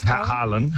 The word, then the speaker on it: Haaland: Haaland? – Haaland.